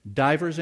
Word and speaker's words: divers: Divers, anybody got a King James? Divers is spelled D-I-V-E-R-S. Divers temptations. Now the reason it says divers is because diverse is various.